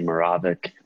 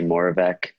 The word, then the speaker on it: Moravec: We've kind of said it differently throughout my family. My dad used to say Moravec. I say Moravec.